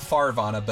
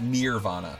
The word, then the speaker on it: Nirvana: Not Farvana, but Nirvana. – Near, like it's yeah. – Right, like adjacent to Vana.